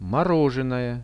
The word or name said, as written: morozhenoje